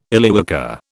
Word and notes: Illywhacker: British English

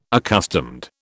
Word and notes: accustomed: British English

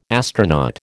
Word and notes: astronaut: American English

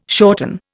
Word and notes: shorten: Indian

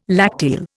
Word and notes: lacteal: Indian

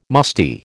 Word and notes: musty: American English

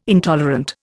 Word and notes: intolerant: Indian

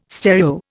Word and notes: stereo: Indian

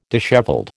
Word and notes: dishevelled: American English

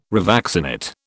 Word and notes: revaccinate: British English

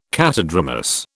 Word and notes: catadromous: British English

